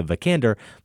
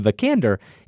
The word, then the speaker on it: Vikander: And the A in Vikander is hard for Americans to say. You say the A kind of in your cheek with twang. We say the A more open, closer to how you say a short U, like in thunder.